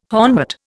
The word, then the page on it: hornwort: Indian